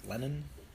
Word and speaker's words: Lennon: And today with us, we have the creator of Creepy Trees Comics, Dylan, and how do you say your last name? – Like Lennon? – Lennon. – Like John Lennon? – Like, yeah, exactly.